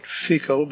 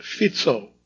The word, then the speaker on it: Fico: Correction to my previous pronunciation of the far-right anti-American Prime Minister of Slovakia, spelled F-I-C-O. It's not Fico, but Fico, as we soon learned because he was shot in an assassination attempt. Some newscasters were still pronouncing it Fico.